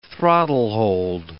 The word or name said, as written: throttlehold